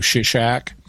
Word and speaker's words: Shishak: In the Bible, he's called Pharaoh Shishak. But that's the Hebrew pronunciation, or based on the Hebrew pronunciation.